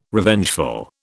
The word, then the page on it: Revengeful: British English